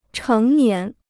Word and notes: 成年: chéng nián

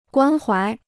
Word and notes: 关怀: guān huái

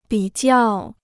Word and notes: 比较: bǐ jiào

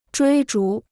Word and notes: 追逐: zhuī zhú

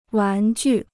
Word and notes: 玩具: wán jù

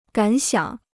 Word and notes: 感想: gǎn xiǎng

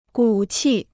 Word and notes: 骨气: gǔ qì

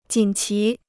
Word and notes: 锦旗: jǐn qí

